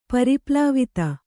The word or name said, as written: ಪರಿಪ್ಲಾವಿತ